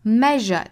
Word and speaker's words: measure: measure 这个词里面的这个字母 s 要发 zh measure measure